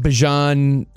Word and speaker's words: Bijan: See, I had Bijan last year. And by the way, that's the correct pronunciation.